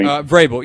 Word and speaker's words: Vrabel: I would say that I would thought we may have actually gone a younger route and go to Vrabel, just who got let go in Tennessee, and I probably mispronounced the name, so forgive me. – Vrabel, you got it.